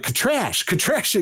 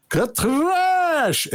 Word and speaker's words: Katrash: And then Eric's like, Katrash again. Well, no, Master Eric, roll your R's. Katrash!